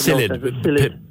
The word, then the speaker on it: psyllid: But I read recently therein that there is a predator of the Japanese knotweed in Japan, and I think you pronounce it, it's spelled with P-H-Y, but it's psyllid. Psyllid.